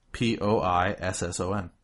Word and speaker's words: poisson: POISSON. Poison. – Okay. – I'm a big fan of it, actually. – What is it? – Fish. – Okay. – I am now aware that it's apparently pronounced poisson.